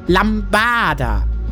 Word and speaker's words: Lambada: Laut Duden liegt die Betonung auf dem A, Lambada, wie es der liebe Waldemar eben gesagt hat.